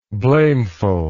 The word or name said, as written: blamefulness